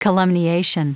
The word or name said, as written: calumniation